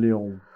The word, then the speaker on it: Lyonne: I believe her name is pronounced Lyonne. It might be Lyon. – Lyonne.